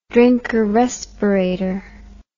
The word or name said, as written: Drinker respirator